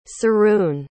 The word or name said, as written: siroun